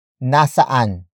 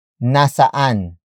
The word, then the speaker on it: nasaan: Word for word, it's nasaan ka na. Nasaan ka na. Nasaan ka na. The first word is nasaan. Nasaan. Nasaan. Nasaan is spelled as N-A-S-A-A-N.